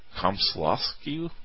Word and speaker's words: Komsomolskoye: I will spell it for you if you'd like. KOMSOMOLSKOYE. Komsolsky, maybe?